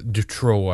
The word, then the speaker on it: Dutroux: Mark Dutroux, which is one, Dutroux, I guess you pronounce his name, an X at the end.